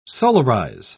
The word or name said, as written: solarize